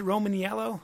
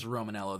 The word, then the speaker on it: Romaniello: Is it Romaniello? – It's just Romanello. The eye is silent.